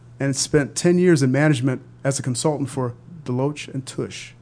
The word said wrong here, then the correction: Deloitte and Touche: Did I say that correctly? – No, Deloitte and Touche. – Deloitte and Touche. Okay, Touche. – Touche.